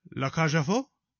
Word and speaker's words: La Cage aux Folles: La Cage aux Folles.